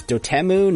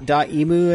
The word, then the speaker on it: Dotemu: It's Dotemu, not Daimu, as we all thought.